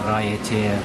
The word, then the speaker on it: Raiatea: So they say that the spiritual center of Polynesia is an island off Tahiti called Raiatea. – Can you spell that? – Oh, Raiatea.